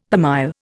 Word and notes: per mille: Indian

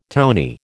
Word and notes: townee: American English

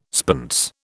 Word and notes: spence: British English

